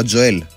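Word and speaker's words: Joel: Λοιπόν, ακούσατε και στο δελτίο επίσημο του Τζοέλ Ρόκα τελικά. Τζοέλ λέγεται και προφέρεται ο παίκτης. Τζοέλ Ρόκα, επίσημα παίκτης του Ολυμπιακού.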